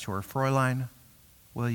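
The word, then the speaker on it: Fräulein: Fraulein.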